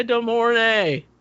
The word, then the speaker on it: De Mornay: Rebecca De Mornay. – Yeah, she's so hot. – It even rhymes with horny.